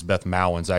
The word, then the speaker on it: Beth Mowins: I guess it's Beth Mowins actually is how you pronounce her name, but mm-hmm.